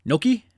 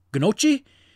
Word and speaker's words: gnocchi: Gnocchi? Ganache? Gnocchi? However you pronounce that word, I'm kidding. It's gnocchi. It's gnocchi.